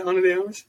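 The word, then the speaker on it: Ana de Armas: Have you seen Anna de Armas? If that's how you say her name? – Yeah, Anna de Armas.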